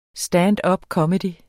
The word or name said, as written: standupcomedy